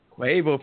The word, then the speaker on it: Quavo: He's upset because, you know, Karrueche is now supposedly dating is it Quavo? That's how you say his name? Quavo? – Yeah. – Yep. Quavo from the Migos.